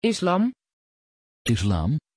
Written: islam

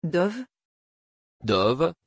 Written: Dove

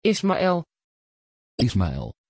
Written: ismael

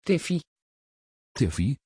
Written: Tiffy

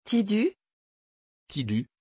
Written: Tidus

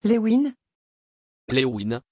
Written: lewyn

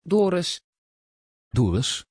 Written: Doris

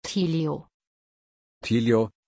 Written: Tilio

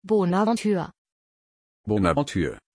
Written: bonaventure

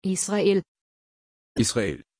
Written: israel

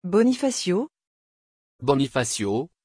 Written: bonifacio

